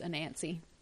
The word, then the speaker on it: Anansi: Okay, but the final word is Anansi.